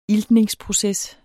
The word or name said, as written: iltningsproces